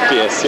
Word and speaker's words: Modiphius: right, so is it Modiphius? Is that how you yeah, pronounce it? Modiphius, yeah.